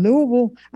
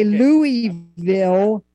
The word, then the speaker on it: Louisville: I used to say Louisville, but they said, no, you got to swallow it and say Louisville.